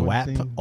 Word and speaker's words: WAP: Is WAP or WAMP? – WAP. I don't.